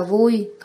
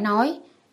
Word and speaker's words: vui: Tiểu Thiên, cái đó đọc là vui, không phải nói, ý là không còn gì vui bằng, ta sửa sai nói.